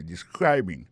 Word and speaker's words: describing: Alors, describing là c'est participe présent verbe to describe ça nous ka wè là ça nous constater là nous ka constater que il a prononcé mot de describing accent tombé sur deuxième syllabe là ça veut dire mot ça a trois syllabes describing so,